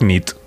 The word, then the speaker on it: nit: Y noche es nit. – Muy bien. – Buena nit. – Buena nit, es verdad. – Buena nit.